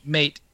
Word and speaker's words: mate: The issue is that Maori language "mate" read as the as a Maori word as well. That word would be pronounced "mate," and it means death.